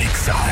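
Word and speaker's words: Exa: Ixa FM 89.9. No se dicen éxitos, se dice Ixa.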